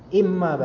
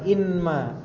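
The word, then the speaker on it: imma (إمّا): Imma, imma asalnya dari kata in dan ma. Kemudian dileburkan nun ke mim seperti apa namanya? Digunakan ya menjadi tasydid imma bacanya, bukan inma, imma bacanya.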